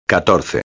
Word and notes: 14: espanhol

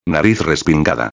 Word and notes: respingada: espanhol

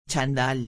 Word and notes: chandal: espanhol

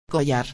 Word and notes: collar: espanhol

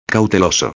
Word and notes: cauteloso: espanhol

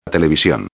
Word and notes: television: espanhol

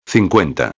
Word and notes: 50: espanhol

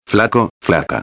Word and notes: flaco/a: espanhol

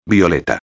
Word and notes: violeta: espanhol

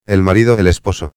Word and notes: el marido el esposo: espanhol

